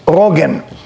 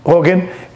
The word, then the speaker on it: Rogen: Seth Rogen or Morgan? – Rogen. – Rogen.